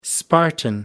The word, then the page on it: spartan: American English